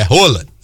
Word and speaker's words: Haaland: Porque a gente aprendeu que não é Haaland que se fala, é Haaland, né? Que é, o próprio Haaland falou isso, que não, a pronúncia em norueguês é Haaland.